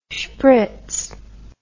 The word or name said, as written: schpritz